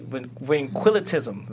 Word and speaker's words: ventriloquism: ventriloquism. Is that if I'm pronouncing it? – Ventriloquism. – There you go.